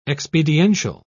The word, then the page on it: expediential: British English